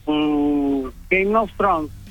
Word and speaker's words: Game of Thrones: Game of Thrones. – Muy bien. – ¿Será? – Juego de Tronos. – Bien. – Gracias. – No sé si lo pronuncié bien. – No, perfecto, está perfecto.